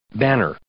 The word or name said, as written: banner